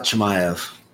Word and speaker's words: Chimaev: Chimaev? Chimaev? Yeah. – Hamzat Chimaev.